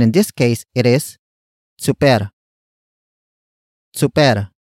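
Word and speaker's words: tsuper: Some Tagalog speakers pronounce it as chuper.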